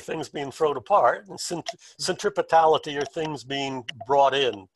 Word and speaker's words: centripetality: And the key word there is one that I'm struggling to pronounce, centripetality.